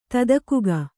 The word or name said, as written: ತದಕುಗ